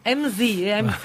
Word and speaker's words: MZ: MZ예요. – Z 발음이 G가 아니에요. Z예요. Z.